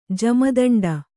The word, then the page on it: ಜಮದಂಡ: jama daṇḍa